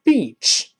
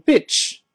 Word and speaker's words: beach: 啊，另外注意这个 beach 沙滩这个词，b e a c h，这个呃容易把它读成 bitch，这个不行，